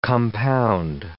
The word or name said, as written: compound